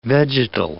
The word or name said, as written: vegetal